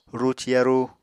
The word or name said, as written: ruci yarú